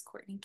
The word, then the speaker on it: Kourtni: So on Instagram and Facebook, it's Courtney King.